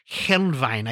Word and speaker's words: Helnwein: He was heavily influenced by Gottfried Heinlein. Helnwein, I can't ever pronounce that.